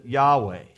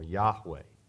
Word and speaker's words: Yahweh: We oftentimes pronounce it Yahweh. You may have heard that before, Yahweh. In the UK, oftentimes, especially some of the old scholars, pronounce it Jehovah. So Jehovah or Yahweh, this is the proper name of God.